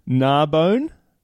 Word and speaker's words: Narbonne: Narbonne? Where's that? – No idea. – Am I not pronouncing it right? – No, you're pronouncing it right, yeah.